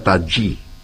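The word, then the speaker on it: tajy: Se escribe Taji con J, Y, pero me han dicho que se pronuncia Taji.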